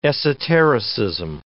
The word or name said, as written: esotericism